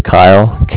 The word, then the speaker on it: Kyle: That is Kyle, K-Y-L-E, mm-hmm. calling here with the Bank of Cardiff, and Cardiff is spelled C-A-R, like car, D-I-F-F, mm-hmm.